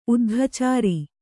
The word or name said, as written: ಉದ್ಘಾಚಾರ